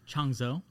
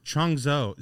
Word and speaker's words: Changzhou: More than 80 captive ostriches uh, escaped from a farm and were seen racing through the streets of, how do you pronounce this? – Yes, Changzhou. – Changzhou.